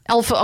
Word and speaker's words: Elphaba: Elphaba.